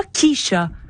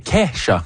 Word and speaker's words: Kesha: C'est pas Keisha. – Non, c'est Kesha.